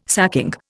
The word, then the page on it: sacking: Indian